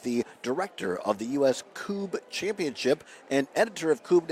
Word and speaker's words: Kubb: It looks like Cub, K-U-B-B. Is pronounced Coob.